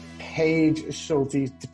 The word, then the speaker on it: Paige Schulte: Today we have Paige Schulte.